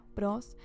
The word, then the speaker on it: ProZ: También uso PROS, o PROZ se escribe, como una Z, PROS, en la parte de glosario.